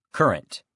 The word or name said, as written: current